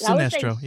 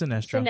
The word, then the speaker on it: Sinestro: It's Sinestro. Yeah, it's Sinestro.